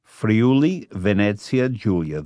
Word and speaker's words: Friuli Venezia Giulia: Friuli Venezia Giulia.